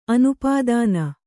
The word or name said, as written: ಅನುಪಾದಾನ